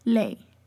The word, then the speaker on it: lei: But if it's an E-I, then it sounds like a long A sound. – Lei.